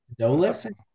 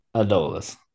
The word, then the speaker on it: Adolis: Dolis, Adolis Braden? – Adolis.